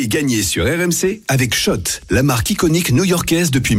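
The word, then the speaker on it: Schott: Je vous rappelle également que demain soir, dans le quiz de l'After, on vous offre votre bomber Shot. – Oh. – Parce que c'est comme ça qu'on prononce. – On dit pas Scott.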